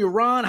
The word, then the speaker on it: Yaron: It's Yaron, Yaron.